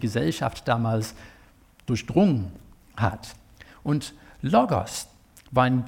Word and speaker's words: Logos: Und tut mir leid, nein, es wird nicht Logos ausgesprochen, das wäre eine andere Schreibweise in der griechischen Sprache. Also Logos ist es.